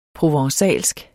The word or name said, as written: provencalsk